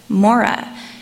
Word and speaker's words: Marah: The Syriac translation of Exodus 15 shows that Marah was likely pronounced as Mora, which is pronounced like the first half of the name Mormon.